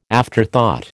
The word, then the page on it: afterthought: American English